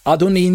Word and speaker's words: Adoni: But the vowel markings are different, thus the pronunciation is different, and it turns out to be Adoni. And so this word, pronounced as Adon plus E-E, double E, Adoni nearly always refers to human superiors, but both of these are translated as Lord in your Bibles.